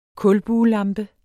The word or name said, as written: kulbuelampe